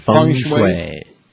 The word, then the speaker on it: Feng shui: No, it's spelled here F-E-N-G. – That's Feng. – How you spell it, but it's pronounced Feng Shui, trust me.